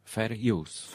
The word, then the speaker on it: fair use: Fair use. – Fair use. Właśnie. – Czyli tak jak fair play, tylko fair use.